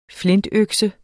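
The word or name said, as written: flinteøkse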